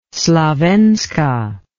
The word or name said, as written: Slavenska